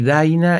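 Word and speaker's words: Dinah: Y Daina es un tema, un foxtrot que escuchaba a mi papá, que le gustaba mucho, pero que es el Dina con H al final, que se pronuncia en inglés Daina.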